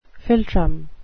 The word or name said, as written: philtrum